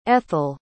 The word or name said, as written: ethyle